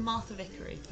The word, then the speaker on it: Martha Vickery: Martha Vickery. – Martha, M-A-R-T-H-A. – V-I-C-K-E-R-Y.